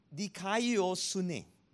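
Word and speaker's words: dikaiosune (δικαιοσύνη): The word righteousness comes from this word pronounced dikaiosune.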